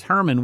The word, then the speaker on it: Hermon: Now, you wouldn't get that because Hermon, we pronounce it with just the H, the K is silent, mm-hmm. but Hermon is actually spelled K-H-R-M-N.